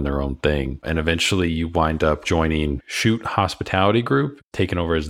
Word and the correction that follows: Shooty: It's pronounced Shooty Hospitality Group.